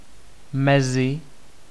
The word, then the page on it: parmi: /paʁ.mi/